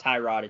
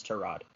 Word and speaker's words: Tyrod: He did an interview, and he said, no, my name is not Tyrod, it's Tyrod.